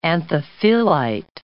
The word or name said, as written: anthophyllite